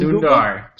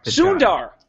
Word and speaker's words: Sundar: Sundar Pichai? Did I say that right? – From Sundar. – Sundar! God, I was so close.